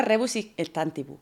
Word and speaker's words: Rebus Sic Stantibus: la cláusula Rebus Sic Stantibus, su desarrollo y potencial regulación en el ordenamiento español.